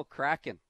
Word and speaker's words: Kraken: The Seattle Kraken. If I'm pronouncing that right, I think I am. – You are. That's correct.